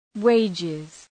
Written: wages

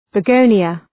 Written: begonia

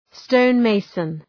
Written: stonemason

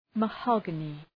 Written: mahogany

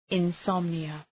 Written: insomnia

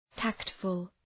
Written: tactful

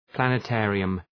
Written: planetarium

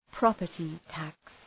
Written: property tax

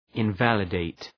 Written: invalidate

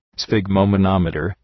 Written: sphygmomanometer

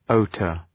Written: hauteur

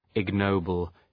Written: ignoble

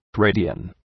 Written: radian

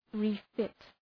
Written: refit

